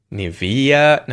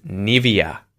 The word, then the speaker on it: Nivea: Nivea? No, it's Nivea. Nivea. The emphasis is on the first syllable. Nivea. Nivea. – English native speakers usually say Nivea. Nivea. In German, we say Nivea.